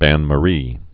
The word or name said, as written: bain-marie